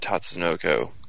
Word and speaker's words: Tatsunoko: And if you look like at a show like C, which I particularly liked, that's done by Tatsukuno. That's, I'm trying to remember if that's the right pronunciation for that Tatsunoko.